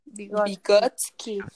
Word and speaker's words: Vygotsky: Vygotsky.